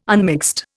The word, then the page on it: unmixed: Indian